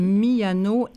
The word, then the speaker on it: Miano: Miano, M-I-A-N-O, donc vraiment comme ça se prononce, et Leonora avec un A, oui.